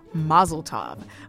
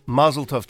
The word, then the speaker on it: Mazel Tov: Well, the answer is Mazel Tov. Tell us more about it. – Well, first of all, Mazel Tov to you because you pronounced it correctly.